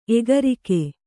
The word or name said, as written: ಎಗರಿಕೆ